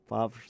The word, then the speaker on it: Favre: How do you say that? Favre. – Favre is good. – Favre, I think, is how they pronounce it.